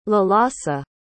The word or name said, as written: Lalasa